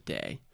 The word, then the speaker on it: Dei: and they both said Dei. – Okay. – So that's how I'm saying it right now. – Gotcha. Okay. – But if you say Dei, we understand.